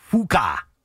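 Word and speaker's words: Fučka: There was a guy who played in the Olympics in 2000, and his name was Gregor, and his last name was Fuka. How do you spell that? – F-U-C-K-A.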